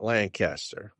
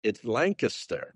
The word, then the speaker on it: Lancaster: First of all, it's not Lancaster, all right? – Well, New Yorkers pronounce it that way, sorry. – Yeah. It's Lancaster.